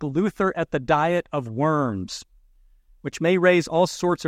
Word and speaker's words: Worms: Worms, pronounced Worms in the German language, was a city in Germany where Luther was put on trial for his teachings.